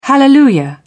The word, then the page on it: hallelujah: British English